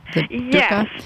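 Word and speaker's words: dukkah: You were talking a lot about the nigella seeds, and you talked about dukkha. Am I saying that right? The dukkha? – Yes.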